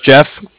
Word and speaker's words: Jeff: My name's Jeff, J-E-F-F, with the Bank of Cardiff. That's spelled C-A-R as in car, and then D-I-F-F as in Frank.